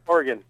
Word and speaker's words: Oregon: Oregon.